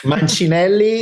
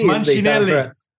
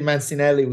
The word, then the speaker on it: Mancinelli: Mancinelli. Mancinelli. But I appreciate Mancinelli, which is how 99.9% of people call me here.